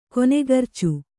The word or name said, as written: ಕೊನೆಗರ್ಚು